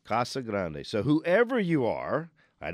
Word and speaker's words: Casa Grande: We don't have the words Casa Grande in English dictionary, so they need to be pronounced as the Spanish words that they are. The correct pronunciation of Casa Grande and the town named this is Casa Grande.